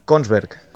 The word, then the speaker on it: Kongsberg: Kongsberg.